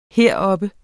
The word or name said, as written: heroppe